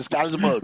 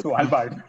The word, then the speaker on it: Svalbard: Skalsberg. – Svalbard.